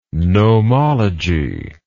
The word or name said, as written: gnomology